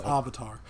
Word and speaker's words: Avatar: Also, it's pronounced Avatar.